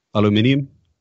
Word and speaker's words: aluminium: Can you pronounce aluminum the way the Brits do? – Aluminium? – That's not bad.